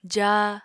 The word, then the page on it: jaa: apatani